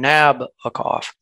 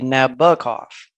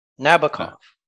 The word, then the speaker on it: Nabokov: I did look it up because I realized, thanks to that great British poet Sting, I have probably been mispronouncing it, tending to put the stress on Nabokov. But I looked it up and he said the emphasis is on the middle syllable and it can be either a hard O or a soft O. The soft O is his preference, so I guess it's supposed to be Nabokov. Nabokov.